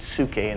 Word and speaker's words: psyche: The word soul in the Greek is spelled P-S-Y-C-H-E. Psyche. They pronounced it psuche in the Greek. We pronounce it psyche.